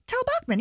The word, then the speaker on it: Tal Bachman: What we got is, I can't say his name, Tal Bachman? – Tal Bachman, yeah.